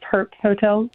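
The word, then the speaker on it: Herpe: Wow, and this is the Herpy Hotel, correct? – Oh, yeah, that's right. – Herp with a silent E at the end, so just Herp Hotel.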